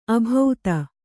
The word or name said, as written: ಅಭೌತ